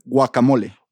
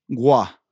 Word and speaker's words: guacamole: So the correct way to say it is guacamole. – Guacamole. You're not, like, say the first, just the first letter. Pronounce that first, that G. – Gua. – Gua. Gua. Guacamole.